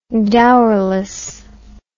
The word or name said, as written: dowerless